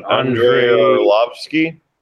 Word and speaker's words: Andrei Arlovski: And well, the one guy whose name I'm not feeling confident enough to uh, pronounce uh, was On- Andrei Arlovski.